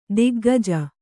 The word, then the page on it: ತಗ್ಗಜ್ಜಿ: taggajji